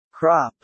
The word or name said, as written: crop